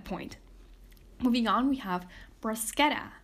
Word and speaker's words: bruschetta: So in this case, bruschetta has three syllables. Bruschetta. Once you break it down into syllables, look for the stressed syllable. Bruschetta. Bruschetta. In this case, it would be the ketta.